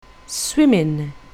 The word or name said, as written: swimming